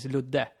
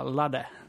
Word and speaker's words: Ludde: Eh, fast, fast inte Ludde, utan jag säger eh, Hi, my name is Ludde. Jag liksom körde svensk betoning på det. Ja. För det låter ju bara Ladde. Det låter ju konstigt. I'm Ladde, I'm a Swedish game journalist from, from Sweden, you know.